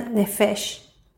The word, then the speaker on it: nefesh: Pero aquí donde dice, dame a las personas, la palabra aquí, eh, personas, en la traducción hebrea, en la traducción original, es nefesh. Se pronuncia nefesh.